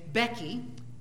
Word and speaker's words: Bheki: And there's a fellow there named Becky. Yes, he's a guy, and it's not spelled B-E-C-K-Y. It's spelled B-H-E-K-I.